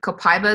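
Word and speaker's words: copaíba: Copaiba is the second one. Copaiba is or as my cousins would probably in Brazil would probably yell at me because it should be pronounced copaiba, but it's a highly inflammatory oil.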